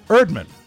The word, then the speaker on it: Erdman: Erdman. – E-E-R-E-A-R-D-M-A-N. – Because he explains. – Erdman. – Yeah. – Which, in his own words, rhymes with nerd man or turd man.